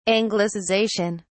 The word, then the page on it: anglicization: British English